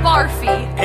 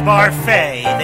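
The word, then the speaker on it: Barfée: Returning after last season's tragic setback, William Barfee. – It's pronounced Barfay. There's an accent ado.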